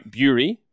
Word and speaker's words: Bury: Bury, because it's pronounced Bury. It's like Fury. So Fury, Bury. So Jason Bury.